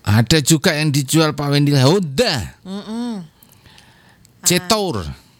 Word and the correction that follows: Jetour: Cetol bukan. Jetor. – Jetor. – Jetor. – Oh, Jetor. – J-E-T-O-U-R. Jetor.